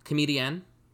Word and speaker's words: comedienne: You're putting the emphasis on the "an." – Yeah, because it's spelled C O M E D I E N N E.